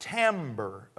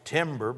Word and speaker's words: timbre: I don't know the exact timbre of voice. T-I-M-B-R-E. It looks like it's timber, but it's pronounced timbre.